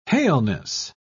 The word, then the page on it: haleness: British English